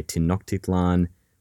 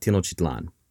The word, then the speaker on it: Tenochtitlan: Sometimes people say Tenochtitlan or Tenochtitlan, but we're going to go with Tenochtitlan because frankly, it rolls off the tongue better. In the year 1519, Tenochtitlan was, by some estimates, the largest metropolis in the world.